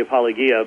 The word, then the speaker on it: Apologia: Well, I had always had fun with the pronunciation because it should be Apologia, but...